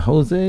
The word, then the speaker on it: Jose: And also they're going to be showing One Pint at a Time, which was produced by Aaron Jose, is it Jose? – It's Jose. – All right, because it's spelled with an H.